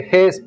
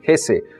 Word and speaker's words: Hesse: Siddhartha de Hermann Hesse, como ya un amable aprendiz me corrigió que no se dice Ges porque en alemán se pronuncian todas las E, así que es Hermann Hesse.